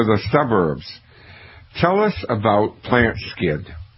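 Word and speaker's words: Plantskydd: It's pronounced Plant Skid. It's spelled Plant, S-K-Y-D-D, which is a Swedish word meaning plant protection.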